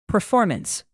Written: performance

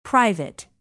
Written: private

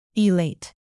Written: elate

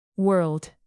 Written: world

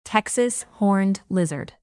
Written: Texas horned lizard

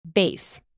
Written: base

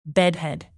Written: bedhead

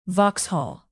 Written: vauxhall

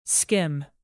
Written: skim

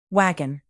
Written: wagon

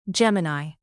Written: gemini

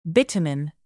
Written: bitumen

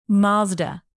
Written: mazda

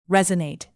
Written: resonate

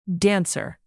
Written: dancer

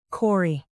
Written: quarry

